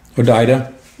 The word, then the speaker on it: Hodeidah: Hodeidah, H-O-D-E-I-D-A, Hodeidah, the port city of Hodeidah, basically eliminated the entire oil depot there.